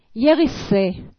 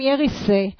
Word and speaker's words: גיריסה: מכל מה ששמעו הצ'יז בטרון בלהיט היווני הענק, הם הבחינו רק במילה אחת, יריסה. כותבים גיריסה, אבל לא מבטאים את הגימל, ואומרים יריסה.